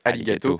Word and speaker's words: Arigato: C'est, le titre du son, c'est Arigato, qui se prononce Arigato. Parce qu'on se disait, ouais, c'est vachement proche de merci et tout. Et en fait, voilà, le R se prononce L, du coup.